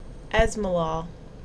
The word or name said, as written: Esmolol